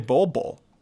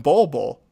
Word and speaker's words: Bol Bol: I find it interesting that you put the emphasis on the second bowl. You say Bol Bol. – Is that interesting? – It is very interesting because I call him Bol Bol.